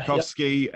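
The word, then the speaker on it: Pucovski: So of the 19 players who were selected for the tour of South Africa, there's no Moises Henriques, there's no Pukowski, is that how I pronounce it? – Yeah, Pukowski, yeah. – Pukowski, yep.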